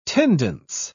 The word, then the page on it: tendance: British English